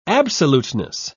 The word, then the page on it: absoluteness: British English